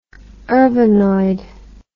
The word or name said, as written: urbanoid